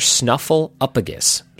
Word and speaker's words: Snuffleupagus: This, Mr. Snuffleupagus, which I don't think I've ever pronounced it the right way. Mr. Snuffleupagus. So it's S-N-U-F-F-L-E-U-P-A-G-U-S.